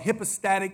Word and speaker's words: hypostatic: To give you a word to impress all your friends, that's called hypostatic union. It's spelled like hippo, but it's hippa.